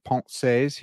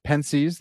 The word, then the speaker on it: Pensées: We have the proper pronunciation of Pensées here for those who hate the way I say it. We also have the based version, Pensées, the way that American swine like me pronounce it.